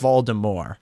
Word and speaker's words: Voldemort: And you don't and more is death in French, and it's not pronounced mort, even though there's a T on the end. So it's mort. – It's Voldemort.